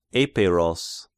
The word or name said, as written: Epeiros